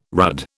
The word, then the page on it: rudd: British English